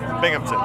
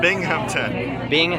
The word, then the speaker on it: Binghamton: Is that right? – Binghamton. – How do you say it? – Binghamton. Yeah. – Oh, I spelled it wrong. How do you spell it? – B-I-N-G. – Oh. – H-A-M. – Oh. Why didn't you tell me? – Sorry, man. – Binghamton. – Yeah, Binghamton. Okay.